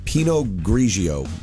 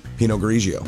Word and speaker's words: Pinot Grigio: And when they got her to jail, a corrections officer discovered that she had a full bottle of Cupcake Vineyards Pinot Grigio. Did I say it right? I'm not a wine woman and chocolate. – Yeah, you're good. – Pinot Grigio.